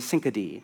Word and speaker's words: synecdoche: You see something similar in Paul's writings, where the cross becomes what theologians call, I believe the pronunciation is a synecdoche,